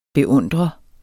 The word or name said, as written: beundrer